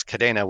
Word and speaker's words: Kadena: Yes. Kadena. It's a nod to the Spanish word for chain and the Spanish pronunciation. – Well, that is cool. It has an E, so the E is really sounding like an A, which is why I had to make sure I did it right.